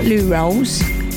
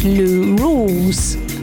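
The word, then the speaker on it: Lou Rawls: And it is, I'm going to pronounce this, it's not Lou Rolls, it's Lou Rules.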